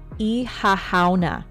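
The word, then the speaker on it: Oíche Shamhna: Ihahauna. Ihahauna. See, now you understand why I go, oh dear God. Because it's not really spelled the way it's pronounced, like at all.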